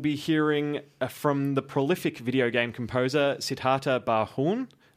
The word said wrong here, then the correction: Barnhorn: But am I saying that correctly? – Barnhorn, sorry.